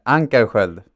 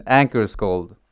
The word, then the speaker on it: Ankerskjöld: Uh, Ankerskjöld. – Anchor cold. Am I saying anchor cold or I can say it in English instead. Anchor's cold.